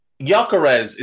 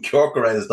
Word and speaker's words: Gyökeres: On 66 minutes, Victor Gyökeres is apparently the correct pronunciation. I've been calling him Kyorkarez the whole time.